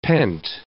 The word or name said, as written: pent